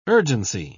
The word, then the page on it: urgency: British English